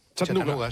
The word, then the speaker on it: Chattanooga: ¿Y se pronuncia así en Chattanooga? – Chattanooga. – Sí.